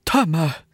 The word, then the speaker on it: Temu: Vad heter det? Temu. – Ja, du vet den här kinesiska appen. Oh. Tydligen är det så man uttalar det.